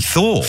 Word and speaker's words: Thor: Lewis in Gloucestershire wants to put a shout out to Thor's Cave? How would you pronounce that? – Probably Thor.